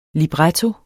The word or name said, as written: LIBRETTOEN